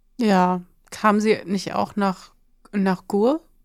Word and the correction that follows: Gurs: Nach Gurs, ja, genau. Gurs. – Oh, ich habe es falsch ausgesprochen.